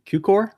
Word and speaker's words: Cukor: Good old friend George Cukor, is that how you say it? – Yeah.